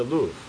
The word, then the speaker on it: Louvre: Louvre.